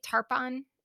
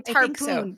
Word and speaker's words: Tarpon: Tarpon? I was thinking Tarpoon, but it's not Tarpoon. It's Tarpon. There's only one O there.